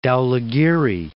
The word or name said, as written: Dhaulagiri, Mount